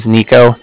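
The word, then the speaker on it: Niko: Again, my name is Nico. That's N-I-K-O.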